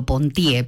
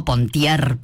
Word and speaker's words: Pontier: Si lo dijéramos en francés, sí sería Armando Pontier, pero bueno, cuando él surgió a la fama, quedó como Pontier, porque es así como se pronuncia en nuestro país, ¿no?